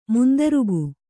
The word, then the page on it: ಮಂದಾರಿಕ: mandārika